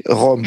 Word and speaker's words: Rom: So it is this second R, which is at the beginning of the name of our people, Rom. We say Rom.